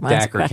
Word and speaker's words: Dacher: Dacher Keltner.